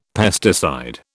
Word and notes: pesticide: British English

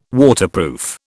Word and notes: Waterproof: British English

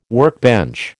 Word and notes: workbench: American English

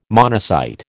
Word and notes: monocyte: American English

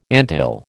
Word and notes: anthill: American English

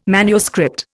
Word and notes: MS: Indian

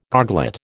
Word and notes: goglet: American English

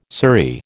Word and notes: surrey: American English

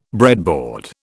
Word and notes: breadboard: British English

